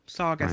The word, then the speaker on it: Saugus: I learned about Saugus. – Saugus. – Rhymes with August. – Saugus.